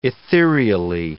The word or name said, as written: ethereally